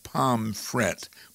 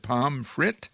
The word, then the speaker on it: Pomfret: I was saying more like Pomfret, but I noticed you said Pomfret. – Yeah, I think most people pronounce it almost like an I, but I think it's just it's got probably a little bit quicker way that when people pronounce it, it comes out as Pomfret. I think either way is okay, but I think most people in the area, it comes out sounding a little more like an I probably.